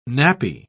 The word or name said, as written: nappy